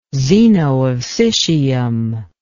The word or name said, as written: zeno of citium